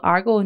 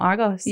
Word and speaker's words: Argos: Did I ever tell you the time when I used to call it Argo, not Argos? – Yes. Yes. – Silent S.